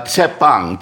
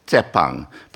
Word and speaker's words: Tshepang: Tshepang. You don't actually make it's not SH, it's rather TS. Tshepang.